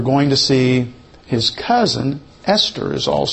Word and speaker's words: Esther: And they gave this book the name Esther. In the Hebrew, it's not TH, It's just T.